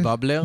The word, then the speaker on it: בבלר: ובעצם לליאל, איך מבטאים את השם משפחה? – ליאל בבלר. – בבלר, שנהרגה בתאונת דרכים בשנת 2017.